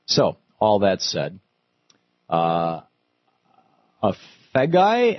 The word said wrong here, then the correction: Afagai: am I saying that right, in Huntsville, Alabama? – How do you do? It's Afagai. – Afagai, how are you? – I'm well, thank you.